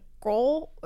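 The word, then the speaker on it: Grød: It's spelled G-R-O with a line through it, D, and it's kind of, I'm going to butcher the pronunciation, but it's like gruel. It's supposed to sound like gruel, like porridge kind of a thing.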